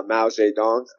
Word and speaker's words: Mao Zedong: And then uh, Mao Zedong, I think I said his name right.